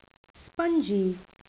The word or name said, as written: spongy